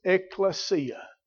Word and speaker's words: ekklesia: That it's translated from a little Greek word pronounced ekklesia. Ekklesia.